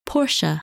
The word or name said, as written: Portia